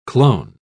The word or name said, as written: clone